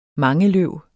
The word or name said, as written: Dryopteris